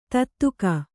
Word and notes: ತುತ್ತಿಕ್ಕು: kannada

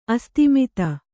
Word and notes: ಆಸ್ತಿಮಿತ: āstimita

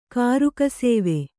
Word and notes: ಕಾರುಕಸೇವೆ: Kannada

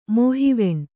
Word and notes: ಮೋಹಿವೆಣ್ಣು: mōhiveṇṇuy

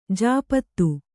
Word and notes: ಜಪಿತ: Kannada

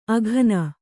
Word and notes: ಅಘನ: Kannada; aghana

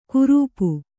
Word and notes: ಕುರುಪು: kurupu

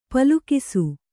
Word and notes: ಪಲುಕಿಸು: palukisu

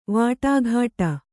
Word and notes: ವಟಗುಟ್ಟು: vaṭaguṭṭu

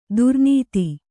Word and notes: ದುರ್ನಾತ: durnāta

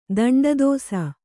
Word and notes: ದಂಡದೋಸ: daṇḍa dōsa

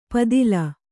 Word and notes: ಪಿತ್ತಿಲ್: pittil

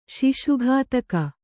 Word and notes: ಶಿಶುಘಾತಕ: kannada

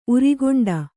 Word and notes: ಉರಿಗೆಂಡ: Kannada

